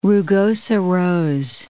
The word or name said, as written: rugosa rose